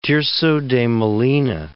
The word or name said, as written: Tirso de Molina